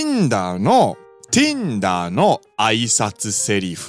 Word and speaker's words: Tinder: n ンダー。日本語発音でティンダー。